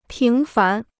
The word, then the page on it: 平凡: píng fán